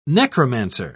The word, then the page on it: necromancer: British English